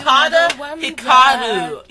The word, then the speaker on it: Utada Hikaru: It's not Utari Hikaru. – I said Utari Hikaru. You weirdo. – It's Utada Hikaru. You stupids.